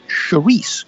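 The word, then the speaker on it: chouriço: Charisse is spelled and looks like chorico, but it's pronounced Charisse or Charisse.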